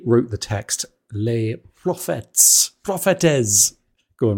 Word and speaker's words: Prophètes: The irony here is that you're confused by the fact it's spelled with a T. But when he was, from the time he was, that was pronounced as a C, so it's prophecies.